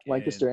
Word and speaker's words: Lancaster: And yeah, guys, if you are in, is it Lancaster, PA, right? – Yep, Lancaster. And you said it right, dude.